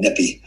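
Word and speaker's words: Nyepi: they have a holiday here called Nepi. And so Nepi is basically, it's a daily of Nippy. – N-Y. – Yeah. My wife's over here telling me how to spell it. Just know it's called Nippy.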